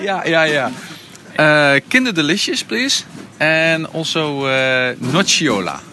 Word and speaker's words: Nocciola: hoe spreek je dat uit, Sander? Nocciola. – Nocciola.